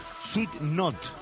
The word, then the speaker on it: Sweet Notes: Hay una banda nueva de K-pop, en realidad no es nueva, sino que es del año 2022, se llama Sweet Not, o sea, se escribe Sweet Notes.